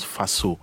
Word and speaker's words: Faso: Faso, c'est normalement Faso. Oui. Mais en français, comme c'est un seul S, beaucoup disent Faso, ah. Mais nous, on le prononce Faso. – Faso. – Parce que c'est en Djoula, et ça veut dire la maison du père.